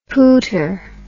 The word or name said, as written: pooter